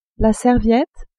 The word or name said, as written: serviette